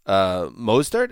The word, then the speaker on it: Mostert: Mostert, is that how you say it? – Right. Yeah, yes.